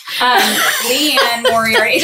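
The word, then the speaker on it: Liane Moriarty: Leanne Moriarty? – Yep. Leanne Moriarty. It doesn't look, it's like not, phonetically. – I guess it is phonetic, actually. Moriarty.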